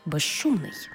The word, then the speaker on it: безшумний: У словах безшовний і безшумний, свистячий з плюс шиплячий ш всередині слова разом вимовляємо як жи-ши, безшовний, безшумний.